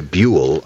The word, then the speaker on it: Buhl: B-U-H-L, by the way, Buell. I only know how to say it because I used to live there.